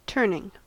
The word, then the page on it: turning: American English